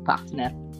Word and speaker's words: partner: Un partner, mi hanno corretto, mi hanno detto che pronuncio male la parola partner. Mm. Vabbè, scusa, piccolo inciso. Chi ti ha corretto? Mamma mia. Mi hanno corretto, mi hanno corretto. L'ho sentito la trasmissione, mi hai detto tre volte partner senza la R.